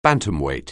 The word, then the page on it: bantamweight: anglais